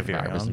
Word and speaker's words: virions: it can end up making 10 billion new virons. Virions? How do you say that? – Yeah, virions.